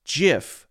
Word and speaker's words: GIF: It is pronounced a soft G, or it is rather a soft G. Let me read that again. It is a soft G pronounced JIF. End of story.